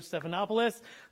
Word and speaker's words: Stephanopoulos: I got it right, George Stephanopoulos.